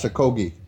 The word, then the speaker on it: Okogie: And here's, oops, I want to give you the actual pronunciation. All right. – Josh Okogie. – I'm going to say it's Josh Okogie. – Yeah. There's really not even a contest.